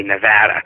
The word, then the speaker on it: Nevada: And I keep forgetting, because I'm from Virginia, I call it Nevada, but you're supposed to say Nevada. It's a flat A. They don't like it if you don't do it that way.